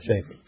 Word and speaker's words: Schaefer: Schaefer, yeah, that's it, yeah, Schaefer. It was one of those hard C words. All right, Schaefer, right, C-H-A-F-E-R, right.